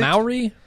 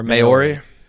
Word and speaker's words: Maori: Maori or Maori? I thought somebody wrote in and said we were pronouncing it wrong. Like it's Maori.